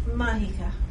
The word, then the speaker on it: Majica: Mahika. – Spell that, please. – M-A-J-I-C-A.